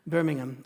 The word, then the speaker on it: Birmingham: The poem that's in the newsletter, when Jesus came to Birmingham, and that is how it's pronounced, by the way, in England, Birmingham, I think nails it, that we would have done the same, perhaps in a different way.